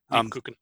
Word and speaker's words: Lebkuchen: Lebkuchen.